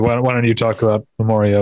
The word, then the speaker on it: Memoria: I've heard it pronounced Memoria by, I would think, people who would know. – Okay, Memoria then.